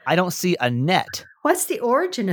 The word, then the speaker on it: Annette: Um, she said it's pronounced Annette.